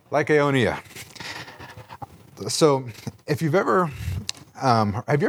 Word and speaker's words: Lycaonia: Lycaonia. – All right, there we go. Lystra, Derbe, and the cities of Lycaonia, and to the surrounding country, and there they continued to preach the gospel.